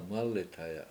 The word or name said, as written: mAlithaya